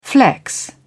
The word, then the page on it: flex: British English